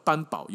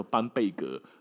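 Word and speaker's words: Bamberg: 另外一个 B E R G 的写法，中文虽然叫堡，但是呢，应该发音叫做贝格。哦，像班堡，有些地图上德国有个地方叫班堡，它应该叫做班贝格。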